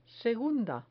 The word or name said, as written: segunda